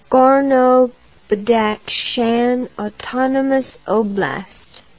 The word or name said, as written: Gorno-Badakhshan Autonomous Oblast